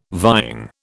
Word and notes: vying: British English

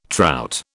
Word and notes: trout: British English

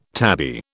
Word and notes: tabby: British English

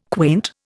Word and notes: quaint: Indian

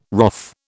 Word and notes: wrath: British English

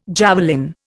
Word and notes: javelin: Indian

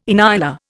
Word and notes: inyala: Indian

